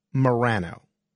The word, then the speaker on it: Morano: Sometimes I pronounce my name Frank Morano. That's the way I pronounce it. Now, my surname is Italian, but a lot of people have asked, why don't you say Morano? Because I guess the proper Italian pronunciation would be Morano. And the answer is as simple as it is boring. Because both my parents, they pronounced our last name as Morano, not Morano.